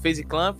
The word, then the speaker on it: FaZe Clan: Mas temos, eu falei certo, FaZe Clan.